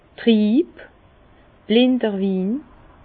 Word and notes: trieb, blinder Win: Bas-Rhin; Herrlisheim

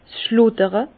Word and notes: 's schludere: Bas-Rhin; Herrlisheim

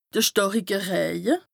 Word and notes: de stàrike Rëje: Bas-Rhin; Strasbourg

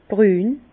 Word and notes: brün: Bas-Rhin; Herrlisheim

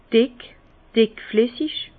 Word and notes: dick, dickflessig: Bas-Rhin; Herrlisheim

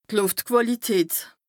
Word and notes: d' Lùftquàlität: Bas Rhin

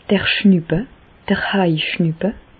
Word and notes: d'r Schnüppe, d'r Haischnüppe: Bas Rhin; Reichshoffen

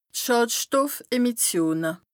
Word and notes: d' Schàdstoffemissione: Bas Rhin

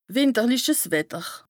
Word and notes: wìnterliches Wetter: Bas-Rhin; Strasbourg

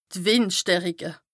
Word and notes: d' Wìndstärike: Bas-Rhin; Strasbourg

